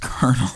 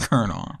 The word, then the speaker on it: colonel: It's colonel. – I've never read that word until today. – But it's colonel. – I can say the word colonel. I just didn't know how it's spelled.